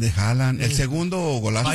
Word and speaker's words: Haaland: Bueno, ya que estamos hablando de Haaland y que no sé qué, el partido de...